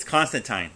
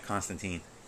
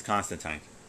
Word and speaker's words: Constantine: Yes, it's Constantine, not Constantine. It's Constantine. So that ends that whole debate.